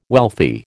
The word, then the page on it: wealthy: American English